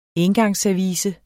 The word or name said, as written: ENGANGSSERVICE